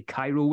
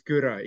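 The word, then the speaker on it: Kyrö: I mean, you spell it differently, and I know a lot of people over here say Cairo whiskey. – Yeah, but it is. You're right. It's Kura. It's almost like got a Japanese pronunciation to it. Kura, like with the very particular Y and the O with dots, the E.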